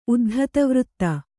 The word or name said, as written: ಉದ್ಧತವೃತ್ತೆ